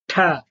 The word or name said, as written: th